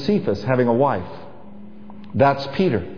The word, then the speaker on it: Cephas: But in Aramaic, his name would be pronounced Cephas. That's spelled C-E-P-H-A-S. Cephas.